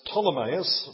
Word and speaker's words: Ptolemais: So it's Ptolemais, not Ptolemais.